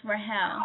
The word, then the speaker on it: Rahiel: The I is silent, so it's Rahiel.